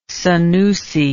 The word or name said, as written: Sanusi